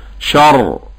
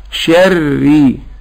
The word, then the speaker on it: شر: يبقى هنطق الرأين اللي بنطقهم وقت التشديد مرققين، وأعاملها عند الوقف معاملة الساكن، فلو وقفت عليها أقول شر شر، ولو وصلت شري شري.